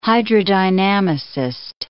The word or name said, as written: hydrodynamicist